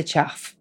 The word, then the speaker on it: chough: It is spelled C-H-O-U-G-H. That is the chuff.